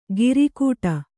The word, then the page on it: ಗಿರಿಕೂಟ: kannada; giri kūṭa